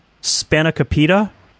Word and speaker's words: spanakopita: It's a Greek spinach pie, spanakopita.